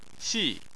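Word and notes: xi: tone 4

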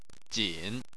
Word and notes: jin3: chinese

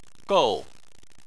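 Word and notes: gou4: tone 4

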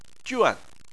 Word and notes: juan4: chinese

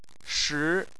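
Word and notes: shi: tone 2

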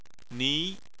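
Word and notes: ni: tone 2

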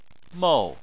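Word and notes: mou: tone 4

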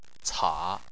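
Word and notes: ca: tone 3

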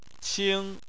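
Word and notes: qing: tone 1

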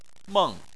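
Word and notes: meng: tone 4

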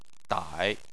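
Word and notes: dai: tone 3

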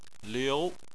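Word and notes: liu: tone 2

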